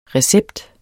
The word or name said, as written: RECEPTENS